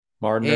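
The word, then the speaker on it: Mardner: Martyr. And it's like guard, like Gardner, but with an M.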